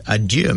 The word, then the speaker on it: adieu: If you look at another language, there is a similar word in French called adieu, A-D-I-E-U, adieu, and it's pronounced adieu, where the word a and dieu means God.